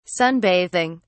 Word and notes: sunbathing: British English

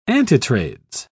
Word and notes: antitrades: British English

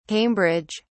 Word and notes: Cambridge: British English

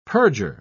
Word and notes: purger: British English

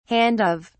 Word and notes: handoff: British English

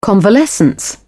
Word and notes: convalescence: British English

